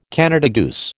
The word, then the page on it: Canada goose: American English